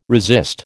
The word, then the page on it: resist: American English